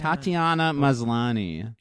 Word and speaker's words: Tatiana Maslany: How do you say her name? Tatiana? – Tatiana Maslany.